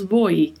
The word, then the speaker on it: voy: Temos que nos atentar aí para a letra V, que tem um leve som de B, e a letra O, que é mais fechada. Voi, voi.